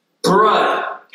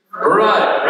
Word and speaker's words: Brodd: So my last name is spelled B-R-O-D-D. And just so we're all on the same page, it's pronounced Brud. Can you say that? One, two, three. Brud, right? So it's actually, you can rhyme it with either stud or crud, depending on how you meet.